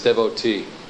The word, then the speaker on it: devotee: The proper English pronunciation of the word devotee is, here's even the division of it. We divide it this way. Right? Devotee. Actual word is dev-o-t.